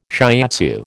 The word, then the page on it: shiatsu: American English